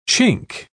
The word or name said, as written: chink